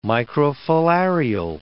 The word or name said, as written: microfilarial